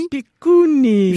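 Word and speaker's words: Mampikony: Vous êtes originaire, on le disait, du nord plus hein, de Madagascar, une petite ville qui s'appelle Mampikouni. – Mampikouni. – Je prononce bien ? – Oui.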